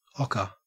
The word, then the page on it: ocker: Australian English